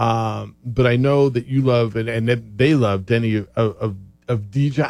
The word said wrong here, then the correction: Avdija: Denny Avdija.